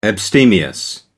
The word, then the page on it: abstemious: American English